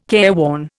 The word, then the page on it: careworn: Indian